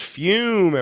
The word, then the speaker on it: Fum: and of course that is Fume, everyone, spelled F-U-M, but pronounced F-U-M-E.